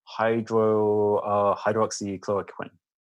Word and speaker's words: hydroxychloroquine: which is hydroxychloroquine.